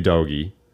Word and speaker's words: Udogie: One is that Destiny Udogie, fantastic signing, it looks like. I liked him a lot. Is that not how you say his name? – No, that's right.